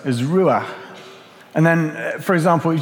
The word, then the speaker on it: ruach: The Hebrew word, word translated to spirit is ruach. I think that's how you say it. It's very breathy. There are a few different ways to translate ruach in the Hebrew, but they all are linked to this same idea that it's about a life force or an energy.